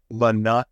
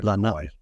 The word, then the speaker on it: Lanai: Lanai. You pronounce all the vowels. – Lanai.